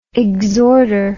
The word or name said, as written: exhorter